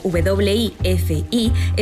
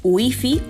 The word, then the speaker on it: Wi-Fi: En España, este préstamo del inglés, escrito W-I-F-I, está totalmente integrado, ya que la grafía inglesa se pronuncia a la española, esto es Wi-Fi o Wi-Fi.